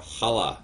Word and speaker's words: Challah: Yeah, and then in the second season it was called The Challah, which I always pronounce because... – Challah. – No, try Challah. – Again. – Challah. – There you go. – Challah. – That's right.